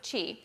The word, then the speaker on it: Twi: It's spelled T-W-I, but they pronounce it Chi.